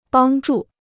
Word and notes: 帮助: bāng zhù

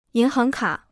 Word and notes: 银行卡: yín háng kǎ

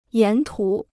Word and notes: 沿途: yán tú

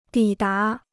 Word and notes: 抵达: dǐ dá

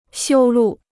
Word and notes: 修路: xiū lù

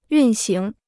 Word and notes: 运行: yùn xíng

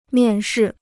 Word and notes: 面试: miàn shì

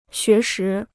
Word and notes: 学时: xué shí

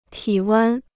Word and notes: 体温: tǐ wēn

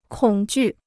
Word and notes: 恐惧: kǒng jù

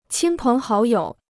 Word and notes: 亲朋好友: qīn péng hǎo yǒu

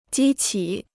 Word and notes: 激起: jī qǐ

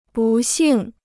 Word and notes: 不幸: bù xìng

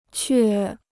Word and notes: 却: què

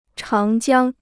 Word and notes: 长江: cháng jiāng

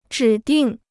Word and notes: 指定: zhǐ dìng

